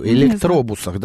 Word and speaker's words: электробус: В Минске классно, на некоторых электробасах при объявлении остановок. Почему я электробас говорю? Электробусах, да? – Ты же учишь английский, поэтому у тебя бас.